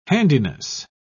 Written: handiness

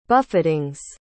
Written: buffetings

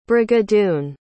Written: Brigadoon

